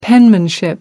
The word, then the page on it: penmanship: American English